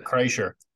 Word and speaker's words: Kreischer: And then there was one last night I started listening to with one of your boys there, Brett Kirshner or whatever. What's his name? – Brett Kreischer. – Yeah, Brett Kreischer. I really butchered his name.